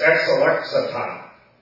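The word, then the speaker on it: ex-alexata (ἐξελέξατο): And this is pronounced ex-alexato. Actually, I pronounced it wrong. It's ex-alexata.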